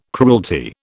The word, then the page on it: cruelty: British English